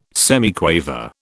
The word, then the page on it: semiquaver: British English